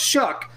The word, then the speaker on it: Shough: S-H-O-U-G-H, pronounced Shuck, okay, Pitt's compete.